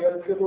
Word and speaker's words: يلفظ: وقوله صلى الله عليه وسلم كم يلفظ الإسلام هو بفتح الياء المثنى من تحت، والإسلام منصوب مفعول يلفظ بإسقاط حرف الجر، أي يلفظ بالإسلام.